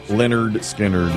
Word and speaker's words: Lynyrd Skynyrd: 53 years ago today, Lynyrd Skynyrd releases this album with Freebird on it, pronounced Lynyrd Skynyrd as well.